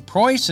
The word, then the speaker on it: Preuss: Uh, our old friends Scott Sosebee and Gene Preuss. Is that how we say it? Absolutely, yeah, one hundred percent. Thank you, because I always mispronounce Gene's name.